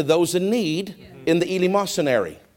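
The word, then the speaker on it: eleemosynary: But they were so poor, they would go to a certain area, and it was called the East, how did I say it? It's actually a place. They would call it the Elemosynary. And after the service, they would go to this place called the Elemosynary.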